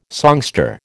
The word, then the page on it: songster: American English